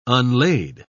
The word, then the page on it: unlade: British English